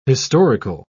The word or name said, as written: historical